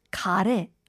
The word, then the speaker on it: 가래: 카테 하는 그 카레랑 같은 발음 맞습니다. It's the same pronunciation as phlegm, 가래.